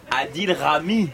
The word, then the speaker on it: Adil Rami: Mais de Atik Raïm. Notre invité. – Comment elle prononce? Adil Rami, c'est le nom.